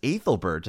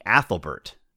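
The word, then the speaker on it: Æthelberht: They say things like AE should be pronounced like the A in cat. It should be Athelbert, not Athelbert.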